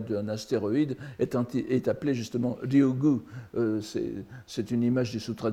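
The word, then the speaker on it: Ryūgū: Enfin, on peut prononcer Ryukyu ou Ryugu euh, ici c'est Ryugu, n'est-ce pas, le, palais des dragons, la lecture bouddhique.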